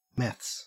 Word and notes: meths: Australian English

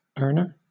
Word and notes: earner: British English

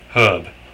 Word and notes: herb: British English

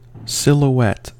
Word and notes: silhouette: American English

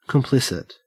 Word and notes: complicit: Australian English

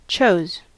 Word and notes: chose: American English

